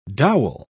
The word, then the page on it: dowel: British English